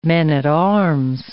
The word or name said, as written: men-at-arms